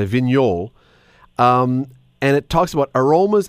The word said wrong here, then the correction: Viognier: You're probably talking about the Viognier. – Viognier, sorry, see, that's, I told you I couldn't pronounce it right.